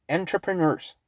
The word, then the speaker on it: entrepreneur: Now, ¿cómo se escribe? Se escribe así. Entrepreneur. It's a very strange word. Weird, weird spelling. Entrepreneur. Y emprendedurismo. Entrepreneurship. Entrepreneur. Así se escribe, ¿verdad? Se pronuncia entrepreneur.